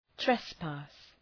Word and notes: trespass: ‘trespæs, ‘trespəs

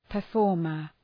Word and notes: performer: pər’fɔ:rmər

